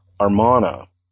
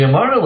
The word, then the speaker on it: Amarna: You've already mentioned them, but, but chapter 2 introduces the uh, Armana, or um, perhaps I'm not pronouncing that right. Uh, no, that's right. That's right. The Armana Archives.